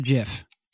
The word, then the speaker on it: GIF: Is it pronounced GIF or JIF? – JIF. – We're team GIF. No right or wrong here. – There is no right or wrong. – Although GIF is right.